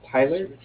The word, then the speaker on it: Tyler: Yeah, I was actually trying to follow up with My name is Tyler, T-Y-L-E-R, and I'm calling with Cardiff. It's spelled C-A-R-D-I-F-F, as in Frank.